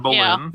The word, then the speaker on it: Boleyn: Anne Boleyn. – Yeah. That new series. – But I don't know where this is going. – Well, the new series of Anne Boleyn on AMC cast an African American woman as Anne Boleyn, and there's two camps.